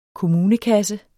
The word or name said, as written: kommunekasse